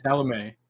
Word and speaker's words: Chalamet: Chalamet. It's French.